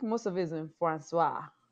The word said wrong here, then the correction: français: What do you mean in Francois? Who's Francois? – The Festival du Voyage. I think most of it's in French. I'm pronouncing that. I butchered that, but Francais. En français. – Francois. – Francois is a name. – Francois is actually French, no? En français. En Francois. Francais.